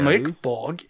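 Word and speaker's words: Mörk Borg: And Borg is pronounced like Mer in murder, and Borg is, well, pronounced like those robot guys in Star Wars.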